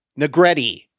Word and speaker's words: Negrete: Gimple, and it rhymes with spaghetti, Matthew Negrete.